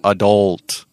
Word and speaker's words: adult: It's a, it's, a, it's a very similar sound. Adult, adult. So think of h 헐 and you go adult, adult.